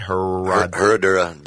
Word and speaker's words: Herradura: Herradura Double Reposado.